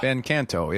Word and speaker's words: Van Canto: V-A-N-C-A-N-T-O. – Van Canto, yeah.